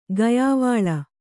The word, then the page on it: ಗೆಯ್ವೊಲ: geyvola